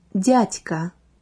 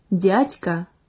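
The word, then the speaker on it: Дятька: So, aunt is Tetka in Belarusian, and uncle is translated like Dyatka. One more time, please. Dyatka. Dyatka. Pay attention to the right pronunciation of the sound D.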